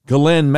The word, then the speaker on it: Ghislaine: Also, of course, Epstein's longtime girlfriend and pimp, Ghislaine Maxwell, that's how you're supposed to pronounce it. It's spelled G-H-I-S-L-A-I-N-E, like Ghislaine or something. But the way you pronounce it, the S is silent, and it almost sounds like G-L-E-N-N.